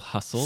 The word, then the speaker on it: hustle: Can you try to spell hustle? – スペルは H-U-S-T-L-E じゃん。 – Ah, very good. – Oh, very good. – That's a tricky one, kind of, because the T is silent.